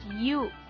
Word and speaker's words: you: y yo les digo, no es yo, es you, pero es súper carcoso porque por más que les trates de explicar que se dice you,